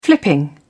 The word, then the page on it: flipping: British English